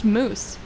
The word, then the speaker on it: Moes: My last name's pronounced Moose. – Moose? – Moose? – Yeah, like how the do we, animal.